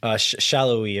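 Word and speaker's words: Sallói: And then the other, their other leader in attack was 16 goals is Daniel Saloy, I think. – Shallowy, I believe is how you pronounce it.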